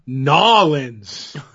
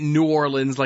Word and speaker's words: New Orleans: And you got to say it right. Nolens. – Nolens? No, I will say it New Orleans like a dumb northerner.